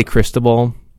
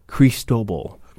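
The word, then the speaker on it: Cristóbal: I would love to say Cristobal. It's spelled Cristobal, but National Hurricane Center and their pronunciation guide, Cristobal. – Cristobal.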